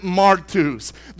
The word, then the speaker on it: martus: The word witness, just to break the word down, in the Greek is spelled M-A-R-T-U-S. To pronounce it, we've got our boy on the Strong's Concordance website. – Martus is the name. I can't roll my R's, but it's Martus.